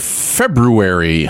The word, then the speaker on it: February: All right, let's head into February. That's right. I pronounced the R.